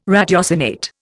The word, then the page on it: ratiocinate: Indian